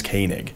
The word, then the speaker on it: Koenig: the guy who played Chekov in Star Trek pronounces it Koenig, and they're always like, oh, okay, then it is Koenig.